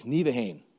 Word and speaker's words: Nieuwegein: And then there's two schools there at the bottom you can see from, and this is actually how you say it, on the left is Groningen, and then on the right is Nieuwegein, both in the Netherlands.